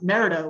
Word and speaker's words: Merida: Like, maybe 5 years from now, you guys will actually pronounce it Merida, which is what it's pronounced as.